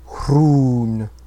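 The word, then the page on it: Rhûn: Sindarin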